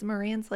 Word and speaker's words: Marantz: So it's Marantz like dance.